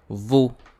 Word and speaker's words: vous: Another thing is with the word vous. As you can see, it ends with the letter S. However, we do not pronounce the letter S at the end.